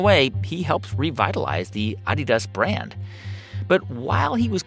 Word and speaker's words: Adidas: He's spent nearly 30 years working for Adidas, which is actually how it's pronounced.